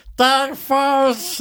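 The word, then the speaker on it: Daeg Faerch: I swear it's D-A-E-G. – Oh, it's like Dag. – F-A-E-R-C-H. – Furch. Dag Furch. That's how you say it.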